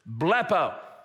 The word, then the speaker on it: blepo: The first, and this begins the progression of saving faith, is the word blepo, spelled B-L-E-P-O, if you were to spell it in English.